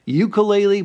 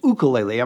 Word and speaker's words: ukulele: So, we must start off with the fact that some people call it ukulele, but the correct pronunciation is ukulele.